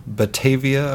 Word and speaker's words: Batavia: Thank you very much, from Batavia, Ohio. Hopefully I pronounced that right. – Yep, I think you did.